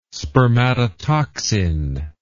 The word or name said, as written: spermatotoxin